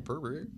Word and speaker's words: Perrier: Oh, we have the fancy Perrier. I can't even say it right. – Perrier.